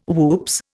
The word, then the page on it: whoops: Indian